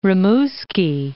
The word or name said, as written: Rimouski